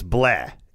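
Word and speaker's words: Blais: Well, it's actually pronounced, it's Blaise. In Canada, he's a French Canadian.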